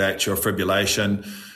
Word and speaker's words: atrial fibrillation: So I'm going to shift it on to, and I'll probably say it wrong again, atrial fibrillation. Mm-hmm. Is that right? Tell us about the condition as an athlete. – Yeah, atrial fibrillation. – Yeah, that's it.